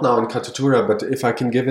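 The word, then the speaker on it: Katutura: We watched the video on your website at the Catatura. Is that the right way to say it? – Yeah.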